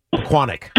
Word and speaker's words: Pequannock: Pequannock. – Pequannock.